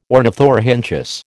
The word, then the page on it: ornithorhynchus: American English